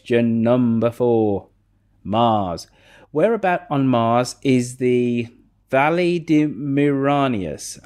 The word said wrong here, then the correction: Valles Marineris: Am I pronouncing that halfway correctly? – Valles Marineris.